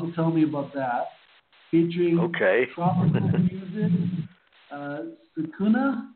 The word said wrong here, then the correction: SAZZUNK: It's SAZZUNK, which is actually a combination of three musical terms, salsa, jazz, and funk, hence the word SAZZUNK.